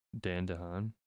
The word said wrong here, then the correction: Dane DeHaan: Dane DeHaan.